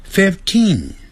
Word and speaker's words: fifteen: 十四就简单了，它也是 t-e-e-n 结尾，所以前面是 f-o-u-r fourteen fourteen fourteen。 15。十五呢，它是从 five 这个字过来，但是改成 fif，所以 fifteen，念成长的元音 fifteen。